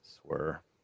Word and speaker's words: SWR: I believe it's pronounced swer, but that's okay.